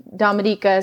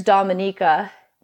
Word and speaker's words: Dominica: There's the Dominican Republic, and then there's Dominica. Commonly, people mispronounce it, but it's Dominica, should be called the Waitukubuli Island.